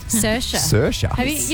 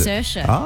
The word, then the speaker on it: Saoirse: Saoirse. You know the actress Saoirse Ronan? But it's spelled like S-A-O-R. It's like spelled like Saoirse. – Is that like a proper Irish name? – It's Saoirse.